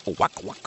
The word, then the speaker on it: Ilwaco: Is that how you pronounce that? Is it Ilwaco? – I think you pronounce it Ilwaco, Washington.